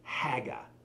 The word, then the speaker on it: hagah: That word is haga. Say that. Haga. Spelled like this in English, H-A-G-A-H, haga.